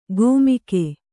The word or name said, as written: ಗೋಮಿಕೆ